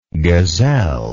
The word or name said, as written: gazellelike